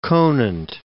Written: Conant

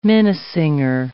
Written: minnesinger

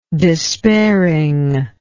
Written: despairing

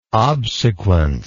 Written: obsequence

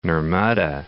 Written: Narmada

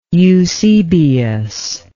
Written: eusebius

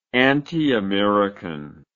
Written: antiamerican

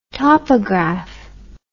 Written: topograph

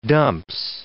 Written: dumps

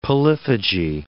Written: polyphagy

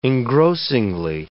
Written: engrossingly

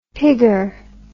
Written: pigger

